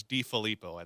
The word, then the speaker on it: DeFilippo: Viking fans would hope it would be option B, but no, it was DeFilippo, I think is how you say his name.